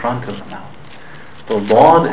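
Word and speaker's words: dod (ض): The letter dod is pronounced from the back part of the mouth, not the tip of the tongue.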